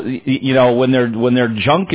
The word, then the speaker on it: Junque: But you have to, when you see the name Junk in the Trunk, you have to know that this is a classy operation. So it is spelled J-U-N-Q-U-E. – That's why I said junk.